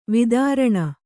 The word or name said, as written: ವೈತರಣಿ